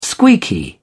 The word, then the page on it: squeaky: British English